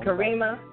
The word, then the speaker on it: Karima: I get to you know, chop it up with an artist like the D Fly and now Kamira. I know I said it wrong. – Karima. – But, you know, Karima. – Kalima. – Mm-hmm. – All right. – Karima. K-A-R. Karima. – Karima. Like Kareem. Okay. Like Karima.